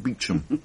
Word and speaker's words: Beauchamp: A lot will depend on Superintendent Beauchamp, pronounced Beecham.